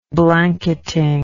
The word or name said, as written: blanketing